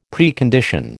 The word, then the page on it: Precondition: American English